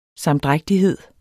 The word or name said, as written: sam-